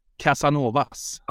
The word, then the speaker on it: Casanovas: In Swedish, we pronounce it Casanovas.